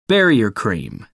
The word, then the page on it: barrier cream: American English